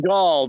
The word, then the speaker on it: Gull: I believe Lake Gull by Brainerd. – Got it. – It rhymes with ball.